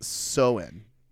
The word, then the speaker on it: Samhain: There are a couple origins, but the one that I think that fits the most is a Celtic festival of Samhain. Samhain. It's actually pronounced Soin.